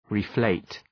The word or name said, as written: reflate